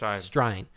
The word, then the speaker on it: Strine: Because that's the first lesson in Talking Australian, is that it's not pronounced Australian, it's pronounced Strine.